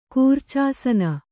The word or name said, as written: ಕೂರ್ಚಾಸನ